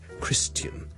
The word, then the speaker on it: Christian: 而基督徒 Christian 咧，我哋上次讲咗，亦都有啲又系老一辈嘅人咧，可能会读成咧 Christian 嗰个发音好似 tian。人咁，你当系好似人啦，跟住前面加好轻嘅 t，OK。